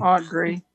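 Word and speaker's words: Audre: and that's where I learned about authors like Andre Lord, for example. I wouldn't have known about uh, Andre Lord. I hope I'm Audrey. – pronouncing her first name correctly. Um, Audrey.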